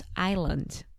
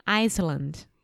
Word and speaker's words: island: It's not their fault, obviously, of saying wrong that it's island. – Island. – Yeah. – And what is the silent letter or what is the mistake that people make? – Iceland.